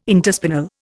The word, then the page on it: interspinal: Indian